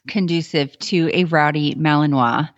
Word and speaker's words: Malinois: Now, this morning, before we got started, as usual, you were helping your Belgian Malinois.